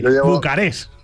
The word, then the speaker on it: Bucarest: Con acento en la última, ¿eh? Y sin la T, Bucarest.